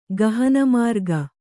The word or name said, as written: ಗಹನಮಾರ್ಗ